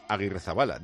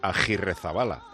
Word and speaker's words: Agirrezabala: ¿Por qué los narradores dicen Aguirrezabala? Dice... que se debía pronunciar Agirrezabala. Es que él no ve la U en el apellido del portero. – Sí, es que en vasco el sonido Agirrezabala es sin U, porque en vasco no existe la U después de la G, como existe en castellano.